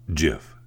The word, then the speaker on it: GIF: It's a, you know, the graphics interchange format, GIF, pronounced GIF, J-I-F.